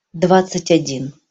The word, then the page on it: dvadcat- odin: Russian